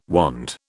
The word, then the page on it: wand: British English